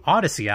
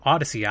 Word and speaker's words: Audacy: That includes Spotify, Stitcher, Apple Podcasts, and the new Odyssey app, which is spelled AUDACY. That's the new Odyssey app.